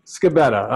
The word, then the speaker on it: Scibetta: I cannot say your last name. What is it? – Scibetta. – I knew it. – See. – Scibetta.